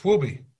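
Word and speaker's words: Huobi: Yeah, but it, I mean, how do you pronounce it? It's H U O B I. It's whooby.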